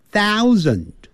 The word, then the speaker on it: thousand: 那就是 1000 1000，你看你有 th 开头，所以舌尖要吐出来啊，然后上齿下齿咬住 thou ou 念成 ow thousand，那个的不要念成 thousand，那个的要念出来的时候你把它切住 thousand，很轻的这样点一下，或甚至把它音切住，不让它能念出来。